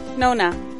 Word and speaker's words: Nona: C-O-T-Y. – And where are you from? – Rhode Island. – What's your name? – Nona. – Nona, can you spell that? – N-O-N-A.